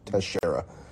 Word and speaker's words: Teixeira: I mean, I know how to pronounce that because there was a baseball player, Mark Teixeira, that was on the Yankees for a while. So I'm pretty sure it's just Teixeira.